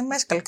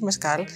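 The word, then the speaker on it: Mescal: Θα δω, λέει, με τον Πολ Μέσκαλ, ο οποίο είναι Μέσκαλ και όχι Μεσκάλ. Γιατί έτσι τον προφέρουν στι τηλεοπτικέ εκπομπέ του εξωτερικού.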